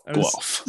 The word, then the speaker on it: Guelph: Guelph.